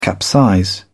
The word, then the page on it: capsize: British English